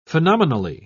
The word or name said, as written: phenomenally